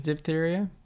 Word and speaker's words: diphtheria: Died in infancy from diphtheria. How do you say that? – Diphtheria? – It is diphtheria? – Yeah.